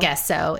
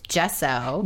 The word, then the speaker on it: gesso: So I took a collage class by Claudine Helmuth, who taught me that it's not gesso, it's gesso.